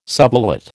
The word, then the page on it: subulate: American English